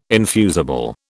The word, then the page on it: infusible: British English